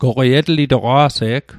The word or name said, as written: etlitoq